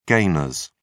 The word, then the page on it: gainers: British English